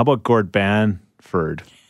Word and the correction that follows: Bamford: It's Bamford. – I've caught you a couple times. – B-A-M. It's not Ban. It's Bam.